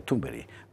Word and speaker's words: Thunberg: Yo sé que Carlos Esteban nos enseñó que se pronuncia Thunberg.